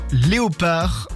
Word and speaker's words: léopard: La lettre qui ne se prononce pas dans le mot léopard, c'est bien le D.